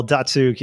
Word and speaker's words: Datsyuk: Datsyuk.